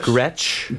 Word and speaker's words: Gretsch: Gretsch.